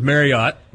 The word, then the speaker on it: Marriott: Marriott, also more colloquially known as Marriott. – But they actually want you to pronounce it Marriott. – Who does? – The Marriotts. It's supposed to be pronounced like chariot.